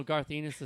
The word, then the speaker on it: Garth Ennis: I know Garth Ennis is writing – is that how – am I saying his name wrong? – Yes. Yeah. No, you're saying it right.